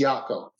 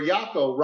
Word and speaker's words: Iocco: My wife's name is spelled I-O-C-C-O. And the Italian pronunciation is Iacco, but it's Americanized. It's Iacco. Like Y-A-K-O.